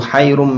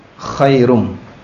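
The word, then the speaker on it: khairum (خَيْرٌ): As-salatu hayrum minan naum, artinya salat lebih membingungkan daripada tidur. Itu kalau bacanya pakai ha, maka hati-hati jangan sampai dibaca pakai ha, harus dibaca pakai kho, khairum, bukan hayrum.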